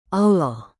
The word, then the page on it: allah: indonesian